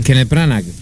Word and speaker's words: Kenneth Branagh: Kenneth Branagh, no sé ni pronunciar el apellido de Kenneth Branagh.